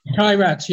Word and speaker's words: Kairat: Kairat, yes.